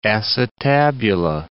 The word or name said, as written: acetabular